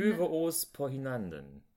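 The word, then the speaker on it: Øve os på hinanden: Äh, Für og Flamm oder wie die ausgesprochen werden, Ove Ospahinanden. – Öve Ospahinanden. – Danke sehr, ja.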